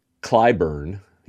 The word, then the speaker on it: Claiborne: The only tears I shed was during when Kurt Russell's character, when Preston Claiborne dies. Michelle Pfeiffer, Stacey Claiborne. Am I saying their last name wrong? Claiborne? – Clyburn. Yeah, it's not like the makeup. Claiborne. It's Clyburn. Clyburn.